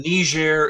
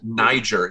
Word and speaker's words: Niger: And Niger is how we pronounce it. Some people might pronounce it Niger.